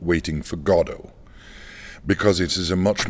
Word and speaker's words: Godot: You know, I've learned that Godot is the correct pronunciation. – It is Beckett's preferred pronunciation in English.